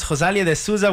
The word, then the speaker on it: Rosalía de Souza: Wow, that was Rosalía de Souza with Que Bandeira. Did I say that right? – Yes.